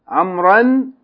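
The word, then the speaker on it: amr (عمرو): Whenever the word amr is marfoon or majroon, you write an extra waw that is not pronounced.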